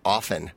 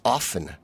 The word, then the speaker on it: often: The one that everybody mispronounces is often. It's often, not often. – Oh, I tend to drop my T's, actually. – The T's not pronounced, is my understanding.